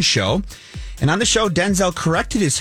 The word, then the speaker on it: Denzel: He said it sounds more like Denzel, D-I-N-Z-I-L. And then he clarified saying that his dad did the same thing as well. So Denzel, not Denzel.